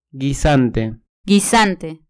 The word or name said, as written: guisante